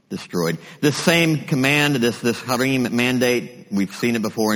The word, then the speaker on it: Harim: If you're taking notes, that's spelled, it's a hard H, A-R-I-M, Harim Mandate.